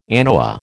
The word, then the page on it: anoa: American English